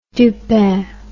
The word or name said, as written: Dupin